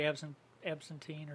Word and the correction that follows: Absinthe: How's it pronounced? – Out of the state. Absinthe.